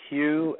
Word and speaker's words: Hugh: My name is spelled Hugh, H U G H.